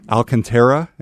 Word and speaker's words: Alcantara: I've got the Surface Go keyboard right here that is in the Alcantara.